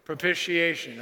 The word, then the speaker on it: propitiation: Let's just say it out loud with me on the count of three. One, two, three. Propitiation, all right? It's right there. Now you know how to say it.